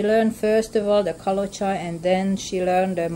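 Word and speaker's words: Kalocsa: It's quite different from the, how do you say it, Kalocha? – Kalocha. – Kalocha.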